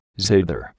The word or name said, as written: zither